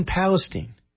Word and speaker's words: Palestine: When he showed up in Palestine. Did I say that right? – Yeah. – Palestine. In Palestine, Ohio.